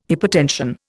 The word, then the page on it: hypotension: Indian